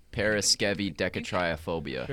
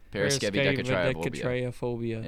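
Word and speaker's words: Paraskevidekatriaphobia: When you take a look at that middle I after the V, it's not pronounced I or I. It's pronounced like a double E. So paraskev-E. – Paraskevi-decatriophobia. – Paraskevi-decatriophobia.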